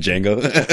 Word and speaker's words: Django: like he's fucking Django. – The D is silent.